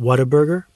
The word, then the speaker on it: Whataburger: In New York, we would pronounce it Whataburger, but either way.